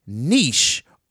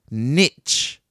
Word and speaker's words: niche: Since then, it's gained a niche, or as I've recently learned, you could say it like this, niche. But I think that's wrong. I think it's niche. I think it's a French word that should be said in a French way.